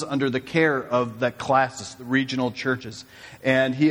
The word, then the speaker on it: Classis: And for you, you're going, did he mispronounce Classis? Classes? Classis is a group of regional churches from Northwest Indiana, Illinois, and I think as far as, Florida now.